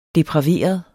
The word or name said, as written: DEPRAVEREDE